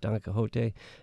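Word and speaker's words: Quichotte: He's got a new novel, a sort of satire play on Don Quixote, spelled differently, but pronounced Don Quixote.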